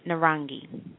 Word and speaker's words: narangi: And orange is pronounced Narani. Narani. And that is spelled N as in Nathan, A-R-A-N-G-I, Narangi.